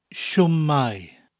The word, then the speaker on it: shwmae: There's one main word for hello, and that's shwmae. Shwmae. Or in the north of Wales, it's often pronounced sit my, sit my. In contrast, there are quite a few words for goodbye. These include hwyl, spelled H-W-Y-L, hwyl fawr, means a big goodbye, because fawr means big in Welsh.